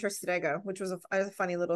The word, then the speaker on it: Strega: But I wanted to start with the fact that you identify as a Strega, and I've probably pronounced that wrong with my super Aussie Bogan accent. – People have done way worse. Strega, that's all it is. Yeah, that's perfect.